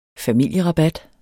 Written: familierabat

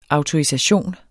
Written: autorisation

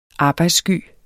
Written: arbejdssky

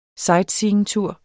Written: sightseeingtur

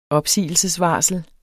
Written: opsigelsesvarsel

